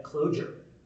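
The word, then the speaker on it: Clojure: I pronounced the language with a harder J to distinguish it from the concept of a closure. – Oh, that's kind of cool. And it makes sense. – Someone, probably Rich, said, no, it's intended to be pronounced the same way.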